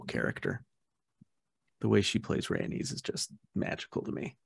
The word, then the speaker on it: Rhaenys: Rhaenys is my favorite character in Fire and Blood. One of my favorite characters in the whole series. And yes, Eve Best is great. Also, it's Rhaenys.